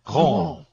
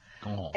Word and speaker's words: Ghent: G-H-E-N-T, but they pronounce it Gaunt. – Yeah. – Gaunt.